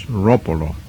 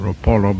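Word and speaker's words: Roppolo: The people who know Italian tell us that it should be pronounced with the accent on the first syllable, so it's Ropolo. Ordinarily or commonly people pronounce it, I believe, Ropolo, but that's not correct.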